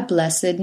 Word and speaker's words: blessed: The following ED words used as adjectives are pronounced with id: aged, dogged, ragged, blessed, learned, wicked, crooked, naked, wretched. So we say, an aged man, a blessed nuisance, a dogged persistence, a learned professor, the professor who was truly learned, a wretched beggar, the beggar was wretched.